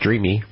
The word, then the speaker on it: Dreame: It's spelled, as you know, Christine, Dreame. So I didn't know if that E was silent or not. And they said, no, it's pronounced Dreamy, like as if it was a Y.